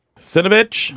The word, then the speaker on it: Cernovich: Cernovich.